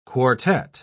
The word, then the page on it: quartette: British English